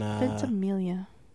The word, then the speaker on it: Ventimiglia: Was Ventimiglia in... – Ventimiglia. I believe it's silent G.